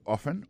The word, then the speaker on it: often: Often times nan se O-F-T-E-N times T-I-M-E-S. Alors se kon sa ou di sa ou. So ou di often nan se O-F-T-E-N malgré l'écrit often, yo prononce often.